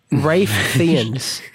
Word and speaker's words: Ralph Fiennes: Rafe Fiennes is Fiennes, how you say his name.